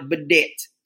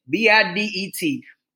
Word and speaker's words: bidet: I call it bidet because it's spelled bidet. B I D E T. They call it a bidet because I guess it's French.